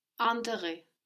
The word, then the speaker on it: intérêt: But we have the IN syllable, no? Like in invité. – Intérêt.